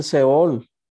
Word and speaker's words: Sheol: Hades es, en hebreo es el Seol. Se escribe S-H-E-O-L en hebreo.